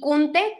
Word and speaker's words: Kunte: Es que mi nombre está un poco raro, pero en TikTok me pueden encontrar como Carla con K, Z y Kunte, que se escribe K-U-N-T-E.